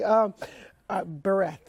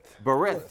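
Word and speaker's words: Berith (ברית): Berith. – Berith, yes.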